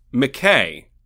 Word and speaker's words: Mike: Zebro tells them that the beast's name is McKay, but it's spelled Mike, which isn't chill, okay?